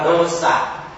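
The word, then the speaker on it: dosa: chữ này đọc là đô xạ.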